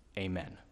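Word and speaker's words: amen (אָמֵן): Let me pronounce the Hebrew word translated sure. It's the word amen. Amen.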